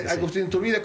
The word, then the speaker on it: Agustín de Iturbide: Agustín de Iturbide, cuando iba como de paso festejando la independencia, ¿no? Entonces, deberíamos pues sí. ¿Lo dije mal? – No, está bien.